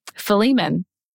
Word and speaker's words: Philemon: Philemon. – It's spelled uh, P H I L E M O N, I believe.